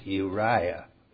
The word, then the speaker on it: Uriah: 16, Zer, U, Rai, See, there's three vowels in an order, but they're all pronounced. Uriah.